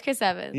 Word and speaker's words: Chris Evans: Yeah, it's Chris Evans.